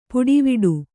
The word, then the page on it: ಪುಡಿವಿಡು: puḍi viḍu